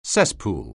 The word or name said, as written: cesspool